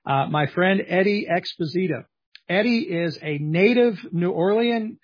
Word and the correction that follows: New Orleanian: I'm not sure I'm saying that right. New Orleanian? – Yeah. – Okay. A native New Orleanian.